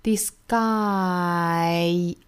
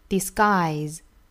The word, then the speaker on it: disguise: Disguise. 然后它的尾音呢是一个 z 的发音，虽然写起来这个字母是 s 哈，但是读起来的话应该是一个 z. Disguise.